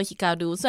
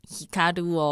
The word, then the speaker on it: 照: Snowman 的队长岩本照伊瓦莫托希卡鲁，虽然汉字写成照，但是念成希卡鲁哦。